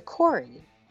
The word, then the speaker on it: Khoury: name Dr. Corey? It's spelled K-H-O-U-R-Y.